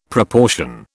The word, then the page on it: proportion: British English